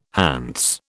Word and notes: Hants: British English

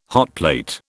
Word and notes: hotplate: British English

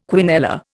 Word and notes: quinella: Indian

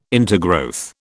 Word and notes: intergrowth: British English

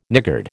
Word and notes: niggard: American English

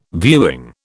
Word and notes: viewing: British English

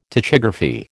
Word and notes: tachygraphy: American English

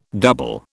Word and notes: double: British English

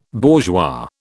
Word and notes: bourgeois: British English

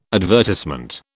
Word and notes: advertisement: British English

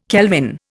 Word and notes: kelvin: Indian

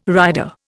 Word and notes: rider: Indian

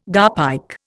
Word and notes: garpike: Indian

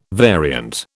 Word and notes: variant: British English

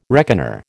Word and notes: reckoner: American English

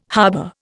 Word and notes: harbour: Indian

